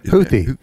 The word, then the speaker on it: Houthi: And do you pronounce them the Houthis? Is that Houthi? – Yeah. Houthi. I think it's Houthi.